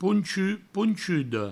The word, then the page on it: ponchut, ponchuda: pounchu, pounchudo